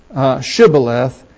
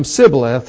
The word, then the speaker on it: Shibboleth: And it says, then said they unto him, say now, Shibboleth, and he said unto him Sibboleth, for he could not frame or pronounce it right.